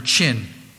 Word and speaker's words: Qin: The Emperor Qin, Q-I-N, it's pronounced, built the Great Wall of China.